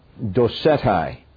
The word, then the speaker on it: Docetae: But the more accepted way is to use the Latin form of the word A-E at the end. That's just my bad typing. It's pronounced the same way. Docetae.